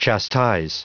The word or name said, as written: chastise